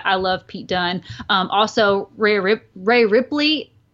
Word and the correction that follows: Rhea Ripley: the excuse me, Rhea Ripley.